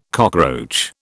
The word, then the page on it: cockroach: British English